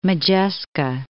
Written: Modjeska

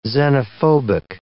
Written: xenophobic

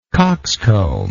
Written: coxcombically

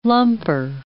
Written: lumper

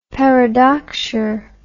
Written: paradoxure

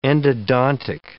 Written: endodontic

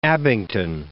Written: Abington

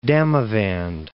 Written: Damavand

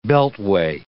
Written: beltway

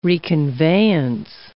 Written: reconveyance

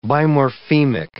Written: bimorphemic

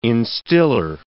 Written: instiller

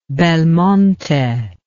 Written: belmonte

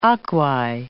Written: aquae

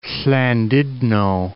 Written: Llandudno

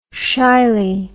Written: shily

